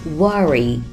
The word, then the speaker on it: worry: Don't 里的字母 T 可以停一拍不发声， Don't worry, W-O-R-R-Y, worry, 表示担心、担忧的意思。大家发音的时候可以控制一下口型，嘴巴呀不要张得太大，说成了 worry, 像这样就可以了， worry。